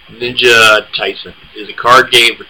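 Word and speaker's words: Taisen: Ninja Tyson, I believe is how you pronounce it. Tyson. – Mm-hmm. – It's T-A-I-S-E-N.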